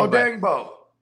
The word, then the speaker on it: Odeyingbo: This dude, Deo Adeyingbo. I'm not even sure how the hell to pronounce his name. – Odeyingbo. – Yeah, there you go.